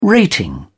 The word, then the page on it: rating: British English